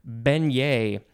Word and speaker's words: beignet: Next we have beignet, B-E-I-G-N-E-T. And I didn't know this, but another proper pronunciation is beignet, with a two long A sounds, beignet.